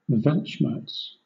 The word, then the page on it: weltschmerz: British English